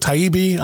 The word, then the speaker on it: Taibbi: Number 11 on the Twitter file drop list from Matthew, is it Taibbi? I'm not even sure how to say his name. – Sounds about right. – Taibbi, whatever.